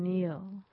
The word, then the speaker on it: kneel: How are you spelling Neil? – K-N-E-E-L.